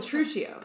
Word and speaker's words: Petruchio: Well, he hails Petruchio.